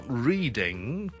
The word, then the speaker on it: Reading: 第一个要特别留意嘅就係 R-E-A-D-I-N-G. It's not reading, but it's reading. Reading, reading, reading. 中文叫做雷丁嘅一个地方。再嚟一个啦。 P-L-Y-M-O-U-T-H 一个在南边海边的一个城市 P-L-Y-M-O-U-T-H 就不是读成呢是 Plymouth It's not Plymouth But it's Plymouth Plymouth Plymouth okay?